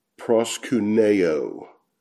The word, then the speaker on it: proskuneo: That word worship there means to kiss the hand, but it's pronounced proskuneo. Proskuneo in the Greek, and that prosku, the ku part, means dog.